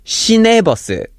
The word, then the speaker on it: 시내버스: So if you listen here, the difference is Shiwe bus and Shine bus. So the second syllable is switching back and forth from we to ne. So the word we is referring to outside, and the word de is referring to inside, and the very first syllable she is referring to the city of Seoul or the city that this bus is in. So 시내버스 is inside the city limit.